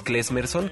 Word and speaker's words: Klezmerson: Es Klesmerson, que se escribe K-L-E Z-M-E-R-S-O-N. Si buscan Klesmerson en, el Facebook les va a aparecer la página eh, del grupo, ahí pueden escuchar música, ponemos noticias, hay fotos.